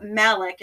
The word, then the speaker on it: Malek: But Malik is how we're going to pronounce his name, mm-hmm.